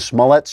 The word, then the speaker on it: Smollett: then demanded Smollett put the accent of his name on the first syllable like a normal person and stop trying to be such a fancy pants.